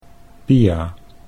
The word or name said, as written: Pia